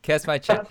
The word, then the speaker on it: Kaczmarek: Close to Kaczmarek. It's like cash my check is kind of the best way to pronounce it. – Cash my check.